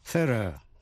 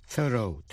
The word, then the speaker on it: thorough: Nhớ là R-O-U-G-H, trong thorough, đọc là thorough, theo giọng Anh. Thorough, theo giọng Mỹ.